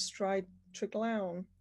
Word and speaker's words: Stride Treglown: So not only are you now national chair of Women in Property, but you're also a director of Stride Treglown. I did pronounce that right, didn't I? – Yes.